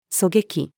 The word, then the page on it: 狙撃: female